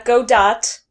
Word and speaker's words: Godot: How do you say Godot's name? Asked and answered. Question two. – Well, it's not Godot. It's Godot.